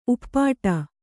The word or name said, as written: ಉಪ್ಪಟ್ಟ